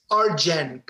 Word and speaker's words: Arjen: No, it's A-R-J-E-N. It's Arjen, but you pronounce it Arjen. The J is an I in Europe. – What's his last name? – Lucassen. L-U-C-A-S-S.